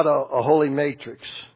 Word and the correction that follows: Mediatrix: Mediatrix. I knew I kept saying that wrong.